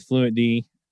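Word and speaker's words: Fluentd: I'm going to butcher the name. I am so sorry. With Fluent D, I guess. I guess that's close. Right. Yep. – Okay. Didn't butcher it.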